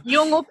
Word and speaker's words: Yolngu: Yolngu people. Thankfully, Yolngu people are ridiculously forgiving.